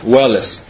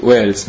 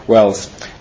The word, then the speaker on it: Wales: Вот он буквально, если по буквам пишется Уэллес, W-A-L-E-S, Уэллес. То есть они говорят, что это вот по английской фонетике Уэльс, да? Уэльс.